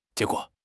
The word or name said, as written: jie2 guo3